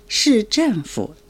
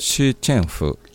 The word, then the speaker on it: 市政府: 市役所は市政府と言います。 – 市政府、はい、発音がいいですよ。